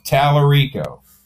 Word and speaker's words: Tallarico: Remember if James Lyrical is running against Ken Paxton there? – Yeah. – And there was a whole... – But say his name properly. It's Tallarico? – Tallarico. – Okay, Tallarico. Okay.